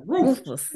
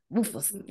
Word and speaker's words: Rufus: Rufus. – Yeah, and then you got to like that. The extra emphasis on the us. Rufus.